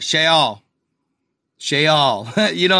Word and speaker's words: Sheol: There are two different ways of pronouncing it. – Two different ways. – This is normally said as Sheol. – Sheol. – Sheol. – See? – In English, however, it is generally said as Sheol. – Sheol. – Sheol. – Sheol. – Or in Hebrew again, Sheol.